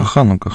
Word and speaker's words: Ханука: Так, и еще, друзья, вот по поводу Хануки, да, я правильно ударение ставлю? – Да, Ханука, Ханука.